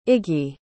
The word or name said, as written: Iggi